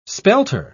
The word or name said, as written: spelter